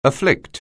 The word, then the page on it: afflict: anglais